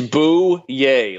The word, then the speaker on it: Bouye: As you mentioned, perhaps the slot matchup may be best with AJ Bouye or Boye. How do you say it? Do you know? – It's Bouye, like boo, yay.